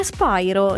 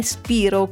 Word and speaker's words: Spyro: E poi le altre curiosità riguardano la pronuncia del suo cognome, che è Spyro, non è Spiro come in tanti pensano.